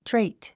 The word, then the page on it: trait: tréit; トレェイト